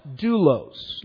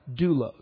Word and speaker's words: doulos: Now the word slave that we have here is the word that we often use for servant, and it is pronounced doulos. And I put the pronunciation on the screen. That isn't how it's spelled, but that's how it's pronounced, doulos.